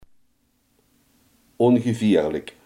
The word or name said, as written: ongevaarlijk